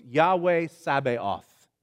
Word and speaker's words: Yahweh Sabaoth: Yahweh Sabaoth is how it's said in Hebrew.